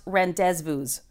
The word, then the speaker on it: rendezvous: It's actually pronounced rendezvous. – Oh, is it? Oh, okay. – Yeah. – Got it. – It's French. You wouldn't get it.